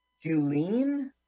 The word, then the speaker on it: Julien: It's not Julian. – How do you pronounce it? – Julian. Seriously, J-U-L-I-E-N.